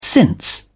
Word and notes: since: British English

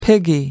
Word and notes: piggy: American English

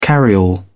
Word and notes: carryall: British English

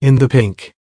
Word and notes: in the pink: American English

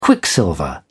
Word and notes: quicksilver: British English

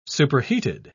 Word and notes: superheated: British English

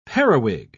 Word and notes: periwig: British English

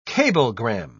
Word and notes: cablegram: British English

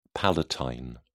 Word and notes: palatine: British English

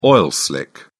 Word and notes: oil slick: British English

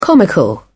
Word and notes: comical: British English

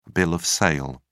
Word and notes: bill of sale: British English

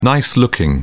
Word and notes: nice looking: British English

